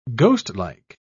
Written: ghostlike

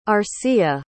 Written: arcilla

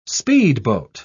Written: speedboat

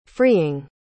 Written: freeing

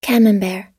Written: camembert

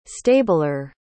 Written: stabler

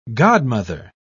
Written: godmother

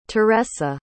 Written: teressa

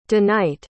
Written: deknight

